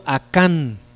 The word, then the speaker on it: Acán: En Josué capítulo 7, léanlo después en su casa, con calma todo el pasaje, narra de una historia de un hombre llamado Acán, con acento en la segunda A, ¿no?